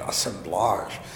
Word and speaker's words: assemblage: That and one of the reasons why I really do favor the term assemblage, you know, even given the French pronunciation, assemblage.